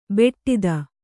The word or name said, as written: ಬೆಟ್ಟಿದ